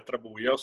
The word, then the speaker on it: Trabuio: As I told before, my name is Andrea Trabuio, so you have the right pronunciation of my surname.